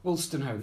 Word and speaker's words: Wolstenholme: Wolstenholme, yes, yeah. As in Kenneth.